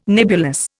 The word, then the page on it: nebulous: Indian